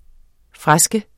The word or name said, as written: FRESKENS